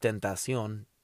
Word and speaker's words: XXXTentacion: How, you know, the right way to pronounce it, you know, in Spanish, Tentacion.